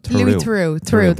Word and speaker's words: Louis Theroux: And for those of you who haven't watched it, it's basically Louis Theron, is that his name? – I don't know how to pronounce his name. – Louis Theroux, that's the one.